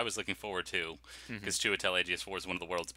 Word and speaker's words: Chiwetel Ejiofor: Chiwetel Ejiofor. – Chiwetel Ejiofor playing Scar, and he's just, you know, reading it dramatically, like, almost sleepwalking.